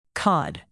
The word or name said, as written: cod